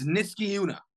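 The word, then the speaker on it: Niskayuna: It's Niska Yuna.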